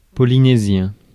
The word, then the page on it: polynésien: [pɔ.li.nez.jɛ̃]; France